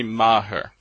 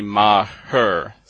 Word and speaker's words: Maher: and with my name it is Jimmy Maher, so you actually say two syllables but I'm much closer to Bill Maher than I am to Mayor.